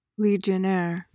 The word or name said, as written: legionnaire